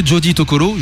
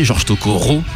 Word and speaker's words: Joji Tokoro: Une chanson qu'on avait donc déjà passée pour, simplement parce que Joji Tokoro, George Tokoro en français, George Tokoro, si tu veux le prononcer vraiment à la franchouillarde,